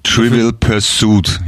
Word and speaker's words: Trivial Pursuit: Trivial Pursuit, ja? Betonung auf dem U, auf dem zweiten.